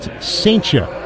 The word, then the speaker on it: Cijntje: Gerangelo Saintia's last name is spelled C-I-J-N-T-J-E. Pronounced Saintia.